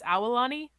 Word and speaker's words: Aulani: How do you say it right? – Yeah, so it's Aulani. So it's Aulani. So you pronounce all the vowels. So it's Aulani. Yeah, I've always said Aulani. Aulani. – Yeah, so it's not Aulani. So you pronounce Aulani. Aulani. Yeah. So you want to? Yeah, it's interesting. The different vowels here. You kind of literally speak them all out.